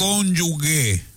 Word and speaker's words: cónyuge: Novoa dice todos los días. Cónyuge. Cónyuge. Y es cónyuge.